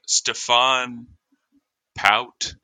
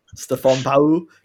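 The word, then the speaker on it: Stéphane Paut: Stefan Pau. They drop off the last syllable.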